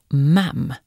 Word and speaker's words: Ma'am: Och Ma'am uttalas med kort A som i engelskan för sylt, jam.